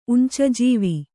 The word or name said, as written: ಉಂಚಜೀವಿ